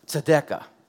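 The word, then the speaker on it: tzedekah (צדקה): The word righteous in the Old Testament in this passage is pronounced tzedekah.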